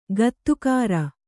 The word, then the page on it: ಗೊತ್ತುಗುರಿ: gottuguri